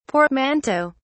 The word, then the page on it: portmanteaux: British English